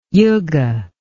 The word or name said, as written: yuga